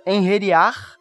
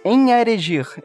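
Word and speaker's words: Einherjar: E é assim que os uh, eh, Enheriar que eu sempre li o nome errado, eu adorei que eu aprendi a pronunciar esse nome com o jogo. Você falava como antes? Em Erigir, eu falava alguma coisa assim.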